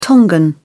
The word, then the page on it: Tongan: British English